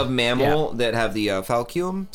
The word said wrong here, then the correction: baculum: Is that how you pronounce it? – I think baculum, right? – Baculum.